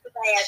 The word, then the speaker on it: Cabello: Camila Cabello, there you go, good.